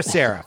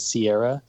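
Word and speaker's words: Serra: Thank you for clearing up how to say the name. I often get SIPS with Sierra, so I appreciate you saying that.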